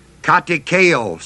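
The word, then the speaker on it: katekeo (κατηχέω): It is the Greek word katekeo, spelled K-A-T, long E, C-H-E, long O.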